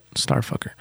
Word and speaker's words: Starfkr: It's actually like it's spelled Starfkr, but Starfucker.